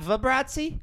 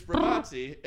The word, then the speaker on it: Bravazzi: it's Bravazzi, and it rhymes with Yahtzee, and it's a hard Italian soda.